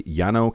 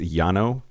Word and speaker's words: Llano: It's Llano County. I think it's LLANO.